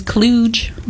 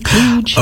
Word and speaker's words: Kluge: It says Kluge. Like a J, Kluge.